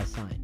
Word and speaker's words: Cygn: And his music is just like the, like, if I had a soundtrack to my life, it would be made by Sign. So it's spelled C-Y-G-N. Yes. Thought I might have gotten that wrong. Spelled C-Y-G-N.